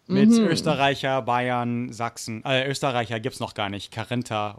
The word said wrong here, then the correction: Kärntner: oder so, aber doch, ja. Kärntner. Kärntner. – Genau.